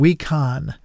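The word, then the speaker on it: Wikan: and John Wecon, as you heard her pronounce it.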